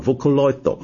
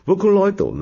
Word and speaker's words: Vocaloid: 我觉得这个他们这个软件做出，这个软件叫做 Vocaloid，但是呢如果日本人读，因为日本式的读音啊，他们就要读成日式拼法，就叫 Vocaloid。哦，是这样念吧， Vocaloid 呢，他们产生出了一大批的这种利用他创作的音乐人，那这些音乐人很多都是不露相的，就是有个艺名，然后呢这种人呢就叫做 Vocaloid P，这个 P 呢就是 producer，音乐制作人的简称。